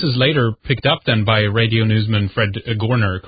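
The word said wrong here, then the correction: Goerner: The best way to pronounce his name is Gerner. – As if it's an E. – It's Gerner. Fred Gerner. And yes, we're skipping one step.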